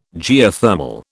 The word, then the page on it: Geothermal: British English